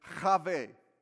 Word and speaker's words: Chave: The first mother we're going to look at is Chave. We pronounce it Eve. E-V-E in Hebrew, it's Chave.